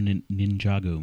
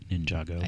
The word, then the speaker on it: Ninjago: Yeah, they had, it's from the new Lego Ninjago movie. Hope I'm saying that right, Ninjago.